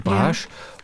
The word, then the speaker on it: Brasch: Hier steht es, Brasch wie rasch, nur mit E.